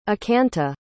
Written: ekanta

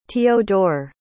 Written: teodor

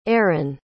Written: aren

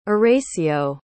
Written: eracio

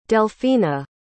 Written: delphina

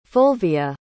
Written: fulvia

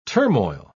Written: turmoil

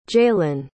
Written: jaylin

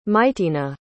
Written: mitena

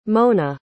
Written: mona

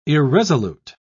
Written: irresolute

